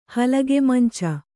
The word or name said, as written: ಹಲಗೆಮಂಚ